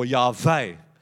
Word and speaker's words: Yahweh: So we get a couple of vowels, A and E, we whack them in there and call it Yahweh, or Yahweh as the proper pronunciation would be.